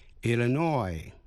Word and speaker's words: Illinois: Illinois, nhấn mạnh vào vần cuối.